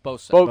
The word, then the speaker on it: boatswain: Boatswain is pronounced bosun.